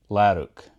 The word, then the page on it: Lerwick: Lerook; Shaetlan